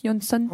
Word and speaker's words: Jansson: Jansson.